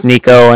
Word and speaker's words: Niko: Like I said, my name's Nico, and that's spelled N-I-K-O. And the Bank of Cardiff is spelled C-A-R-D-I-F-F, as in Frank, so it's like car and then different.